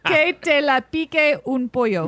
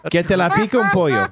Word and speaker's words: Que te la pique un pollo: Que te le pique en pollo. – Nope. – Que te la pique un pollo.